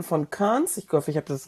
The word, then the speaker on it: Cairns: Also oben hast du schon richtig, Cairns heißt ausgesprochen.